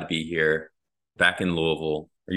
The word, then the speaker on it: Louisville: It's Louisville. It's one syllable. Louisville.